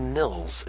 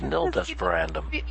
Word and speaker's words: Nil Desperandum: Actually, Nil, why do I keep calling you Nils? It's Nil Desperandum. – Well, I guess because normally when you see it written, there is an S, even if it's a silent S. So you want to say Nils, but it's not.